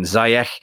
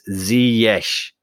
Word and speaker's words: Ziyech: I was calling him Ziyech. It's Ziyech.